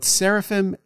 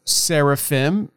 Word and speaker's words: seraphim: That I-M at the end, if you were pronouncing it more correctly, would sound like an E-M, not an M. So instead of seraphim, we would say seraphim. And instead of cherubim, we would say cherubim.